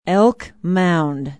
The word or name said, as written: elk mound